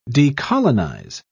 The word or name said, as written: decolonize